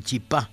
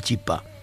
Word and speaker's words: chipá: Después de editar mi primer libro, dice la señora Miró, me di cuenta de que la chipa era el chipá. Algunos dicen el chipá, daicoaimba erepá, pero no se dice la chipa, sino el chipá.